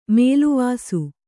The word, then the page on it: ಮೇಲ್ವಾಸು: Kannada